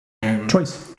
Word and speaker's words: Troyes: There's a little beer shop where a couple of these come from in Twat. – Mm-hmm. – Not spelled like three. – No. Troyes. – Troyes. Yes.